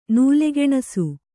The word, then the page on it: ನೂಲೆಗೆಣಸು: nūle geṇasu